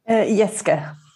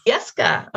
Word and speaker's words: Jeske: Do I pronounce the J, Kirsten? – Jeske. – Jeske.